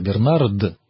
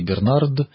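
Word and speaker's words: Bernard: Бернард. Поэтому вот смотря как мы будем произносить по латыни Бернард, на современном французском Бернар.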